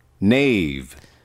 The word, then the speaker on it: knave: K에다가 밑줄 긋고 무금이라고 쓰세요. 예를 들어서 기사 그럴 때도 knight, K-N-I-G-H-T도 knight 이라는 것처럼 K는 읽지 않아요. 칼도 마찬가지고 knife도. 그러면 K는 무금인데 이거를 knave라고 읽어요.